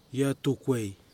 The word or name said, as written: Iatukwei